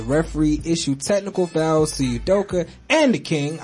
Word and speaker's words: Udoka: LeBron and Udoka, yes, I said his name correctly, thank God.